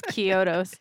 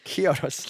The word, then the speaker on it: Chiodos: Chiodos debuted at number 5 on the top. – What? – Chiodos. – Are they Indian? – Chiodos? No. That's my favorite is Kyotos. – Kyotos. – Kyotos.